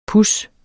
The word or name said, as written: pus